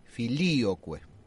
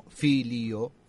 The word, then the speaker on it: filioque: El acento va sobre la segunda I, filioque, y no filio, pero si lo pronuncio solo es filio, filio.